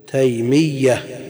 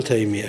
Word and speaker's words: تيمية: وعلى هذا نقول شيخ الإسلام ابن تيمية بالتشديد، ما نقول تيمية كما يقول بعض الناس، لأن ياء النسب مشدد.